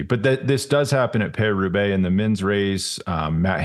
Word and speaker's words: Paris-Roubaix: I always say it wrong. Paris-Roubaix. – I think you got it. Paris-Roubaix. – You did. Okay. You got it right.